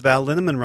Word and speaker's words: Linneman: Val Linneman, Rhymes with Cinnamon, Nick Harper, Stephanie Halmus, Martha Bergner, Nicole Ramsey, I gotta breathe.